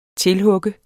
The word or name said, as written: tilhugge